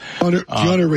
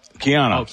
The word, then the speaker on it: Keanu: Am I saying his name right? Canal Reeves? – Keanu Reeves. – Keanu. That's the way you say Keanu.